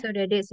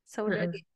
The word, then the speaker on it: Saudade: Bacanya Saudade bukan ya? Aku nggak tahu cara bacanya. – Saudade sih. – Saudade.